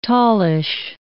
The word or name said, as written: tallish